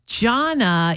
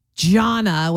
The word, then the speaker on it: Jana: And it's not Jana with a hard J, like Jana, although it would be easier if it was like that. It's Jana.